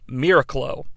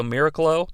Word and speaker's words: Miraclo: Rick Tyler is able to take a proprietary medication called Miraclo. I believe that's how it's pronounced. It's like miracle with C L O on the end, so Miraclo.